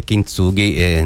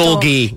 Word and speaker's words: Kintsugi: Buongiorno, sì, perché in giapponese dovresti mettere l'accento Kintsugi. – Kintsugi, sì. In ogni sillaba, sì, capito?